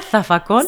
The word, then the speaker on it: zafacón: ¿Zafacón? – Zafacón. Sí, nosotros no pronunciamos la Z, pero sí lo escribimos con Z.